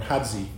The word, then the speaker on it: Hadgi: H-A-D-G-I, or Hadzi, if they use the Greek pronunciation.